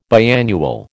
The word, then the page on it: biannual: American English